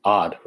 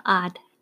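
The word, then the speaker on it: ad: So you can correct me with the pronunciation, but adoption, the first part is odd, right? A-D? – Uh-huh, odd.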